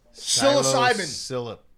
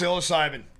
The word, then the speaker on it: psilocybin: No, it's psilocybin. – Yeah. – It's psilocybin.